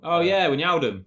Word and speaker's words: Wijnaldum: Wijnaldum.